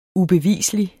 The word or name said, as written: ubevislig